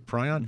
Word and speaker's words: prion: Did I say that right, prion? Mm-hmm.